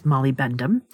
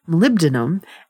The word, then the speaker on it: molybdenum: And now researchers have found that it's able to extract molybdenum, which I always used to pronounce as a child as molybendum, molybdenum and platinum group metals from e-waste or electronic waste, better than conventional bio-based absorbents.